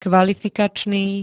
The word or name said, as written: kvalifikačný